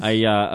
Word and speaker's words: Méliès: George Méliès.